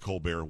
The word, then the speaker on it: Colbert: Yes, I know he pronounces it Colbert, whatever.